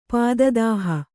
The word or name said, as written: ಪಾದದಾಹ